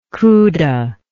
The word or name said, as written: cuda